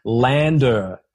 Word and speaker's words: LANDR: Lander, L-A-N-D-R. So, you know, you're walking around on land, or you're driving around on land with the letter R at the end for Rick. Yeah. So L-A-N-D-R.